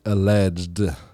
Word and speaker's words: alleged: Senator Robert Menendez, New Jersey, and his wife were indicted yesterday on 3 counts of federal bribery charges related to alleged corruption. Wait, I think you say that with one syllable. You just say alleged.